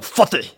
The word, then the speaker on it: Fateh: Of zal ik dat ook een beetje op zijn jopisch, een beetje viezig uitspreken? Oh, Fateh. En Fateh.